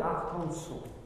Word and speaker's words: Arkansas: Parce que Arkansas, c'est un nom d'origine indienne et on doit dire Arkansas. Hein, et non pas Arkansas.